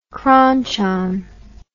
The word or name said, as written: kronchon